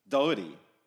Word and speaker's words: Doherty: right, guys, I am here with Lachlan Doherty.